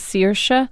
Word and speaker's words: Saoirse: The character she played, her name is Saoirse Ronan. – Saoirse. – But actually, the Irish way to pronounce it is Saoirse.